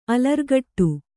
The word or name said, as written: ಅಲರ್ಗಟ್ಟು